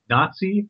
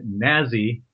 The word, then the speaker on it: Nazi: And I love there's a note on the cover page of this script that says, note, Nazi rhymes with Potsy, and Nazi rhymes with Snazzy. And likewise, the plurals will be pronounced the same.